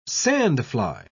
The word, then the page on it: sandfly: British English